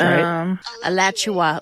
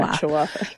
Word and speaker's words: Alachua: Alachua? Is that how you say it? – Alachua, like Joshua, but Alachua. – Alachua.